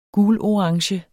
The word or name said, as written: gulorange